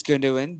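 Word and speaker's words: Gündoğan: I think it's Gundogan. I think it's German. So it's Gundogan. They don't really say the G.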